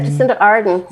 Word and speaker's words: Jacinda Ardern: Is it Jacinda Ardern? – Yeah, Jacinda Ardern.